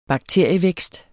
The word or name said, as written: bakterievækst